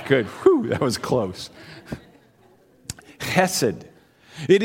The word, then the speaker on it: chesed: In the Hebrew, it's like you say human, and you emphasize the ch. So it's chesed.